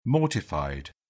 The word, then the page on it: mortified: British English